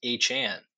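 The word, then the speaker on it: Achane: The breakout rookie running back for the Miami Dolphins has informed the team that his name is pronounced Achan. That's right. It's Devon Achan.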